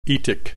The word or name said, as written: etic